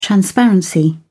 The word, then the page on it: transparency: British English